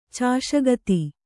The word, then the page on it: ಚಾಷಗತಿ: cāṣa gati